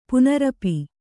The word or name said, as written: ಪುನರಪಿ